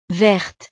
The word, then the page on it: verte: frances